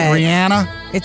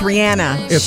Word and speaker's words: Rihanna: Rihanna? – It's Rihanna. She says Rihanna, so that's how you say it.